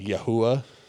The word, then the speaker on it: Yahuwah: It's really similar to Yeshua, but it's like Yahuwah is how they pronounce it.